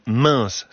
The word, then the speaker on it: Mens: Direction l'Isère ce matin, dans le village de Meins. Ça s'écrit M-E-N-S.